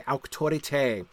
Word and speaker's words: auctorite: So the E's, short E's, are much like modern English short E's, but the long E, as in the end of auctorite, right, that the E is pronounced A, that you get nice, pure, open vowels in Middle English, A, A, E, O, U. So the E's are pronounced A, auctorite.